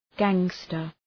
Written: gangster

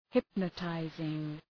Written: hypnotizing

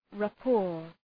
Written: rapport

